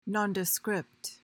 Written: nondescript

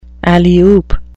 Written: alley-oop

